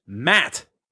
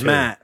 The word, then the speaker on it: Matt: Today's one word was sent to us by Matt.